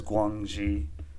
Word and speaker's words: Guangxi: It is Guangxi.